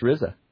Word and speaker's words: RZA: Oh, RZA. I didn't know that's how you say that. – That's how you pronounce it. It's pronounced RZA.